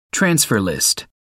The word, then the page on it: transfer list: American English